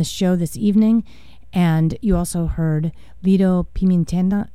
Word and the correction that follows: Pimienta: Man, you know when you pronounce it wrong? It's terrible. It hurts your ears. Pimienta. Lito Pimienta, off her album Miss Columbia.